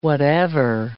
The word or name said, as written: whatever